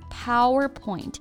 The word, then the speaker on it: PowerPoint: Right, PowerPoint。注意啊，重音是在第一个音节。